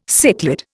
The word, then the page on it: cichlid: Indian